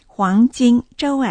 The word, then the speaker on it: 黄金周: 発音ですけれども、黄、 H-U-A-N-G、金、 J-I-N、黄金、周は、 Z-H-O-U、建設音無気音、一世、黄金周、怎么过、怎、 Z-E-N、も、 M-E、形成です。怎么过、 G-U-O、無気音、四世です。黄金周、怎么过。